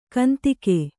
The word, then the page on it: ಕಂತೆಗ: Kannada